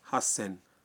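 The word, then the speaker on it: ہس سین (八千): 3000 کو کہتے ہیں سن زین, اور 8000 کا تلفظ ہے ہس سین.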